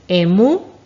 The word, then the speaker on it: emú: Emú. Muy bien, con acento en la U. Emú.